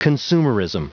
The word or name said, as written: consumerism